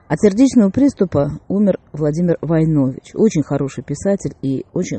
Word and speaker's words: Войнович: Кстати, сам Владимир Николаевич утверждал, что правильно произносить его фамилию как Войнович, с ударением на первый слог.